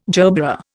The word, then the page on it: gerbera: Indian